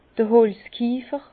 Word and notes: d'r Holzkiefer: Bas-Rhin; Herrlisheim

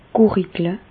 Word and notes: gùrigle: Bas-Rhin; Reichshoffen

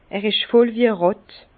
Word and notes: er isch voll wie e Ràtt: Bas-Rhin; Herrlisheim

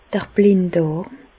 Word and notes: d'r Blinddàrm: Bas Rhin; Reichshoffen

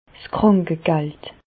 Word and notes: 's Krànkegald: Bas Rhin; Reichshoffen

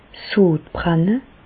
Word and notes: 's Sodbrenne: Bas Rhin; Reichshoffen

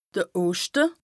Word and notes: de Oschte: Bas-Rhin; Strasbourg

